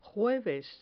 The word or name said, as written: jueves